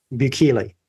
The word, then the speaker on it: Bukele: Well, on Bukele, is that how you say his name? – Bukele, yeah. That's at least how I say it.